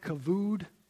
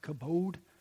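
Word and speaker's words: kabod (כבוד): The Hebrew word looks like kavod, but apparently it's pronounced kabod, that Paul ascribes to God in the final sentence of his letter.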